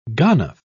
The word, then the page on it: ganef: British English